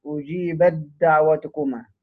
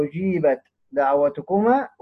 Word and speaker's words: أجيبت دعوتكما: مثال على إدغام التاء في الدال، أجيبت دعوتكما، أجيبت، أجيبت، بنطق بخش في الدال على طول كما اتفقنا. إدغام هو النطق بالحرفين حرفا واحدا مشددا كالثاني، يبقى أجيبت دعوتكما، أجيبت، أجيبت، أجيبت دعوتكما.